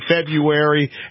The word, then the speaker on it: February: He's trying to say February, and he can't. The first R is silent, man.